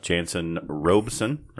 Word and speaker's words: Janson Robeson: And today we have Janson Robeson, right? I said that right? – Yes, sir. – Janson Robeson on the podcast.